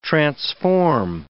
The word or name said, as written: transform